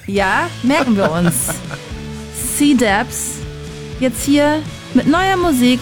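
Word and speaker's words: Cdubz: C-Dubs wird er ausgesprochen. Das C wird einfach ausgesprochen wie das englische C halt.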